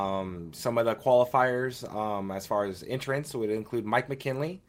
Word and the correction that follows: McKinney: a dirt UMP modified driver. – Mike McKinney. – McKinney, yes, I always say that wrong.